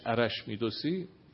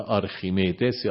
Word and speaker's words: آرخیمیدس: خب این مسیری که دکارت در پیش گرفت، یه تکیگاه به قول خودش عرش میدوسی، این اسم ما بد تلفظ میکنیم، یعنی فکر سنتی جا افتاده و الا آرخیمیدس یا آرخیمیدس